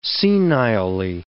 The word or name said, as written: senilely